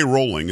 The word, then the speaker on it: Rowling: Rowling, it is pronounced Rowling, it's not Rowling. Everybody stop saying Rowling, it's Rowling.